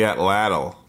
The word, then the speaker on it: atlatl: Oh, the atlatl.